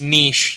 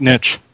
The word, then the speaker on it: niche: They do a lot of imports of or relocalizations of really niche, weird Japanese games. – It's pronounced niche, you twit. – No, I like niche. All in favor of niche, say aye.